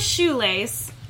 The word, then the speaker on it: Shoelais: So my handle is Shoelace, but S-H-O-E-L-A-I-S.